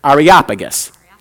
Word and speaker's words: Areopagus: Areopagus.